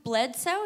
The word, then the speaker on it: Bledsoe: Okay, Tom Brady was the backup quarterback to Drew Bledsoe.